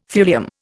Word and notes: thulium: Indian

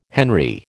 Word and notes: henry: American English

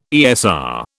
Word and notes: ESR: British English

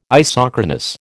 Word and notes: isochronous: American English

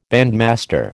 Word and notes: bandmaster: American English

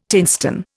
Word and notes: tinstone: Indian

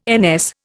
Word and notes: NS: Indian

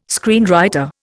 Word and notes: screenwriter: Indian

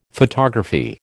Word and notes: photography: American English

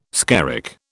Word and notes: skerrick: British English

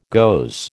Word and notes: goes: American English

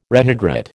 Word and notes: rehydrate: American English

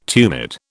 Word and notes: tumid: British English

